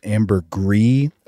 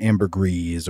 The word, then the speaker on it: ambergris: So I think to a French speaker, it would be pronounced like ambergris, but in mm-hmm. English, it seems everybody says the like a soft pronunciation of the S. So it's like ambergris or ambergris.